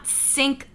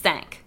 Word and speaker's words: Cinq: It is the mispronounced French word for five, not sink like the kitchen sink. I just want to be yeah. clear about it's that. It's spelled C I N Q, which yes. is supposed to be said sank.